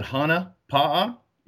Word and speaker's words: Hana Pa'a: You pronounce it Hana Pa'a. It means it's a Polynesian Hawaiian term, but that means fish on.